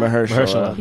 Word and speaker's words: Mahershala: Mahershala? – Mahershala.